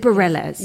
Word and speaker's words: Bareilles: Is it Bareilles? – You said it right.